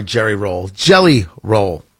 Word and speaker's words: Jelly Roll: I've got an update on what's going on with Iran and this Jerry Roll thing. I'm sorry, Jelly Roll. I got his name wrong.